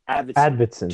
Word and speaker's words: Arvidsson: Arvidsson or whatever? – Arvidsson.